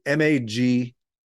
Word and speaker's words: Magnante: So if you don't know, or you haven't been there yet, go over to Steve Magnante, and the name spelling, a lot of people can't get it because they don't even know how to say it, is Magnante.